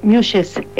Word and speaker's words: muxes: Entonces, o una mujer, en México existen unas mujeres que se llaman, se escribe M-U-X-E, Muxes,